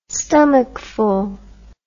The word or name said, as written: stomachful